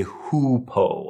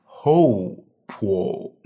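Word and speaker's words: Hou Po: And kind of a weird note on this one, please do not say Hu Po. A lot of people, English speakers at least, will see this and they might think it's pronounced like you, Y-O-U, but it's not Hu Po. We actually have a different substance called Hu Po. In Chinese, this would be pronounced Ho Po Magnolia bark.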